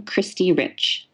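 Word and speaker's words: Khristee Rich: And my Instagram is my name, Christy Rich.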